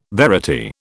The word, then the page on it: verity: British English